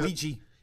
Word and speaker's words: Ouija: Ouija.